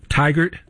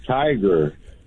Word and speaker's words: Tigard: it almost sounds like tiger, but it's Tigard. It's like T-I-G-A-R-D.